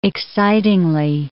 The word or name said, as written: excitingly